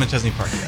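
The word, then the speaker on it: Machesney Park: Did I say that right? The National Guard in McChesney Park? – Yes, yeah, we're the Illinois Army National Guard, but we are based in McChesney Park.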